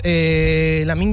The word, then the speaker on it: Lamine: Los hermanos Williams, Lamine Yamal, madre mía, este nombre siempre, yo no sé si voy a acabar diciéndolo bien algún día.